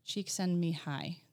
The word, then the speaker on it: Csikszentmihalyi: So he said, his name is pronounced Csikszentmihalyi.